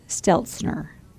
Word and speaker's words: Steltzner: We're bringing in Adam Steltzner.